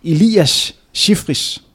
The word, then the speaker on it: Elias Shifris: Jeg håber, jeg udtaler, jeg udtaler det korrekt, men Elias Shifris. Ja, det var meget godt.